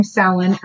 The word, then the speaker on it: Samhain: Halloween has its roots in the ancient pre-Christian Celtic festival of, okay, it looks like Samhain, S-A-M-H-A-I-N, but that's not how you pronounce it. Samhain.